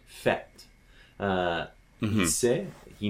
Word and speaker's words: sait: Now, "fête" is uh, F E circumflex T E, and "sait" he knows the T is silent. In fet, the T mm-hmm. is not silent. And so the E is longer.